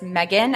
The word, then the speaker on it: Megan: So unfortunately, I don't know why I used it and then it stuck, but it's Megan, M-E-G-A-N, and then A-W-E-E. E-E-Z-E.